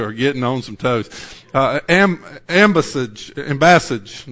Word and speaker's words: ambassage: Or while the other is yet a great way off, he sendeth an ambassage. I'm not saying that. How do you say that? Somebody. English professors. Where are you? Leanne, how do you say that word? Ambassage. That sounds right. Ambassador, ambassage. Michelle's left, so I don't made her mad.